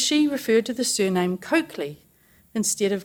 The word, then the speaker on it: Colclough: The Coakley pronunciation was first heard through a chance encounter between one of my brothers and a work colleague.